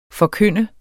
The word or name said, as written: forkynde